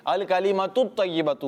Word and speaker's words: الکلمات طیبات: آمین آمین۔ الکلمات طیبات صدقات، اس کی تلفظ کی آپ درستی کریں، ٹھیک ہے؟ الکلمات طیبات ہے، یہ تن نہیں آئے گا اس پر، ٹھیک ہے؟